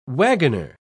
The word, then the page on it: waggoner: British English